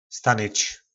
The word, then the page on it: stanyc: po nasimu